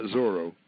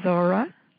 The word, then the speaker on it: Zorra: Z-O-R-R-A. It, some people spell it Z-O-R-A or Z-O-R-R-A. – Oh, okay. – It's not Zorro. Okay. – Yeah. – Zorra. – Okay. – So I'm going to have him, you know. – Okay, we're ready to welcome Zorra.